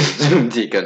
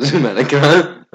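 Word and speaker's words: Zomedica: That's how you pronounce it. Zoomdica, not Zoomedica.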